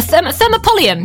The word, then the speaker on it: thermopolium: So it's called a, I'm going to try and say this right, a thermopolium. A thermopolium.